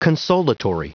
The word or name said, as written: consolatory